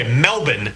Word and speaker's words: Melbourne: The game is Friday morning in Melbourne time, which, by the way, Melbourne is the preferred pronunciation.